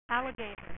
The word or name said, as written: leatherhead